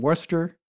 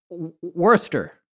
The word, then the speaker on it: Worcester: Uh, well, it's more, I guess, the British pronunciation, but when you do it, it sounds Boston. So, so say the uh, say the other way. – Uh, Worcester.